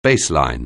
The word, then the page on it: baseline: anglais